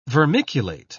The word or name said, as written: vermiculate